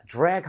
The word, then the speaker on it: drag: 그리고 발음할 때 드래그가 아니라 TR 모음은 그때 치읓 비슷했죠. DR 모음은 지읒 비슷합니다. 입모양만 모아주세요. – 네. – 자, drag 한번 해봅니다.